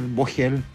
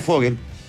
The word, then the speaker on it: Vogel: Bueno, sí, está bien, pero puede ser Fogel, Vogel. Me han dicho de mil maneras. En alemán es Fogel. En castellano eso sería Fogel.